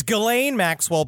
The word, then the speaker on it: Ghislaine: and her name is Ghislaine Maxwell? – No, it's Ghislaine Maxwell, but Ghislaine. – It's just spelled with a jizz in the front of it, which is why Jeffrey Epstein loved her so much.